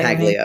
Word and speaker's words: Paglia: Well, wasn't Camille, how do you say Paglia.